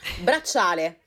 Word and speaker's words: Bracciale: on prononce comment Bracciole Bracciale, ce qui veut dire bracelet en français.